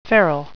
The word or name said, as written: feral